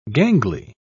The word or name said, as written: gangly